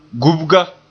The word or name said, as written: gubga